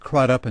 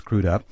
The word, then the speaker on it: Crudup: I'd always pronounced it Crudup until I met him, but it's pronounced Crudup.